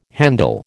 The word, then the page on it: handle: American English